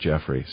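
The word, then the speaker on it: Jeffry: He's Jeff, not Jeffrey, so it's good.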